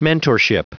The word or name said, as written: mentorship